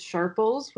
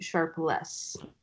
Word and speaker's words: Sharples: Yeah, no, you said my name right, yeah, but my last name is, everybody thinks it's Sharples, which is because of how it's spelled, and it's actually Sharpless.